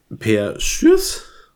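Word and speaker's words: Schuurs: Und Per Schürs? Heißt er so? Wird man denn, äh, äh, äh, spricht man den so aus? Ja.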